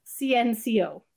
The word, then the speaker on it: CNCO: named CNCO, pronounced CNCO, and taken from the Spanish word for five, cinco.